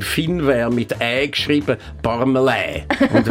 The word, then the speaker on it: Parmelin: Parmelin.